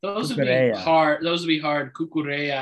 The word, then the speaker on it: Cucurella: It's probably Cucurella. I guess the double L forms a Y.